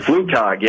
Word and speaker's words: Flugtag: All right, last thing I want to ask you about, is the flug tug. That's a Flugtag, yeah. – A Flugtag. Excuse, I didn't know the German pronunciation of this.